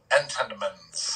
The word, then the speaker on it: Entenmann's: You need to be very deliberate when you pronounce the name of that company. It has to be Entenmann's.